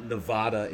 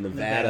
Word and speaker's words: Nevada: It's not Nevada, it's Nevada, Nevada.